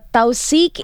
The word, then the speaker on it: Tawtheeq: It is pronounced as Tawseek. It is an Arabic word for attestation. Thank you very, very much, Mujahid, for that message. I knew I would have got the pronunciation wrong, but it's written in a certain way, and that's why in the English pronunciation went like that. It's written as T-A-W-T-H-E-E-Q.